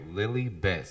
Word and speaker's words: Lilibet: Yeah, I said it right. Lilibet. So it's spelled L-I-L-I-B-E-T. You said she had B-E-T in her mind. – Yeah, Meghan has B-E-T in her mind.